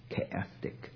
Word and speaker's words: chaotic: 那是希腊文的那个 X 的那音的发音叫做 K chaotic。